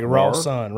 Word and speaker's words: Rawson: Yeah, it's more like Rawson. – Rawson. – There you okay. – go. There you go. – Like Rawson. The son of Ross.